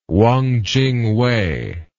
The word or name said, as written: Wang Ching-wei